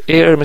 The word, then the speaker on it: heir: heir اچ تلفظ نمیشه heir مثل هوا heir